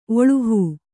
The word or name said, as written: ಒಳುಹೂ